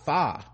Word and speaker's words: pho: And although the soup is spelled pho, P-H-O, it's actually pronounced P-H-I, fa.